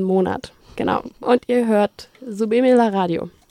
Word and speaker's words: Súbeme la Radio: Und genau, es ist Subime la Radio.